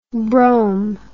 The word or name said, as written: brom